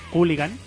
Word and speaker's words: Cooligan: Los compañeros de Cooligan, que lleva dos OEs y dos L's, es decir, se escribe Cooligan, pero se dice Cooligan, así quieren ellos que lo digamos también.